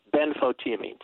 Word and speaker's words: benfotiamine: Oh, Benfo, thiamine, like the word thiamine, T-I-A-M-I-N-E, benfotiamine.